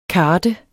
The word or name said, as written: karte